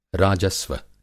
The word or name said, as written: राजस्व